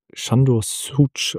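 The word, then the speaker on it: Sándor Szűcs: Ja, ich glaube, der Mann wird Sándor Szücs ausgesprochen, aber ich bin auch jetzt nicht der Beste.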